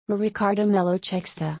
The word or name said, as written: Ricardo Melo Czekster